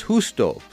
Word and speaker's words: Justo: Well, you know, his name is spelled J-U-S-T-O. For some Americans, you'll say Justo, but it's Justo, Justo El Mario.